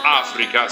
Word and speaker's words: Afreaka: Si chiamano Demon Fuzz, e nel 1970, a novembre del 1970, uscì il loro appunto unico disco, che si chiamava Africa, scritto con freak di mezzo, quindi Afreaka, tanto per leggerlo come si pronuncia.